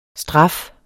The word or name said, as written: STRAFFES